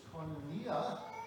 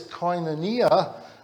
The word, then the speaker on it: koinonia: Its proper pronunciation is koinonia, and it's part of life, believe it or not.